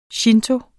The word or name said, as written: shinto